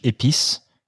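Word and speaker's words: EPICE: So I don't know if they intended to do that, but the effective perinatal intensive care in Europe is pronounced in French, épice, which stands for spice.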